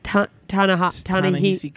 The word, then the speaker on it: Ta-Nehisi: Ta-Nehisi Coates. – Yep. – I'm probably pronouncing that wrong. – I say Ta-Nehisi. – Ta-Nehisi Coates.